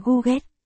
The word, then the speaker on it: Cố ca ca: Rò nữ 8 gọi nam 9 là Cố ca ca, đọc là Google, phát âm giống tiếng con gà cục cục